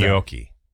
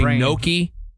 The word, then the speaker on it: gnocchi: People say gnocchi. – Oh. – It's not gnocchi. – Yeah. – Gnocchi. It's like gnocchi. – That's what the G is for.